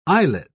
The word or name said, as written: islet